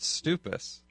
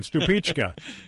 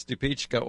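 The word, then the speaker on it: Stupice: The way you guys are pronouncing it, Stupichka. – It's Stupichka or Stupich. So it can be Stupich. Stupich sounds Italian when you say it like that. But it's Stupichka.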